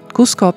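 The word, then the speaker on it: Cusco: Kuzo, nezinu, vai pareizi izrunāja, tā ir pilsēta, Kuzo, Kusko vai, jā, Kusko pilsēta Peru.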